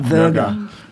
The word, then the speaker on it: Wörter: But one very angry song, and probably my favorite song of the album, is Wörter. I don't know if I pronounce it correctly. – Wörter.